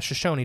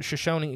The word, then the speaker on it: Shoshone: The Shoshone tribe. I always say it wrong. – Yeah. – Because it's spelled almost shoeshine.